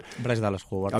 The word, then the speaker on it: Bryce Dallas Howard: igual que Bryce Dallas Howard, lo digo bien. – Bryce Dallas Howard.